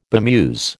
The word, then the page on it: bemuse: American English